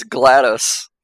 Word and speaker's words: GLaDOS: It's GLaDOS. Like Gladys, but I pronounced it a little wrong.